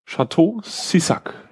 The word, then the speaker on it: Château Cissac: Und zwar im französischen Rotweinfass Château Sissac. Oder? Spricht man so aus, oder? – Ja.